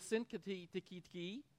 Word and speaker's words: Syntyche: I plead with Euodia and I plead with Syntyche. That's how you say it.